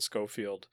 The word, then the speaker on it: Schofield: Yeah, and if anybody missed the news, the hire Glenn that Brian's talking about here is Glenn Schofield.